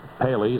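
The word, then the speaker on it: Pelé: I realized as soon as Paley, is that how you pronounce his name? – Yeah. Mm-hmm.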